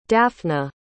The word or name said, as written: daphna